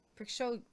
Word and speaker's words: Prigozhin: It's P-R-I-G. How are you getting Pris? – Prigozhin.